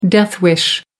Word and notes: death wish: American English

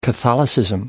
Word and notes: catholicism: British English

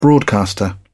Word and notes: broadcaster: British English

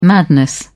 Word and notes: madness: British English

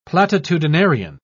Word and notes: platitudinarian: British English

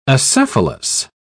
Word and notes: acephalous: British English

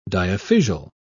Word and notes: diaphysial: British English

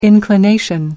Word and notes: inclination: British English

